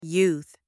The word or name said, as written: youth